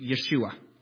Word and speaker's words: Yeshua: When they spoke Hebrew, they were to pronounce the name Yeshua.